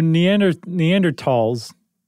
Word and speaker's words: Neanderthal: So, Neanderthals, the correct pronunciation is tall, by the way.